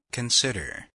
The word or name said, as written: consider